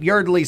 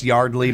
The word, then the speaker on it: Yardley: The great Yardley Smith is 59. It's pronounced Yardley, but I've heard herself pronounce it Yardley.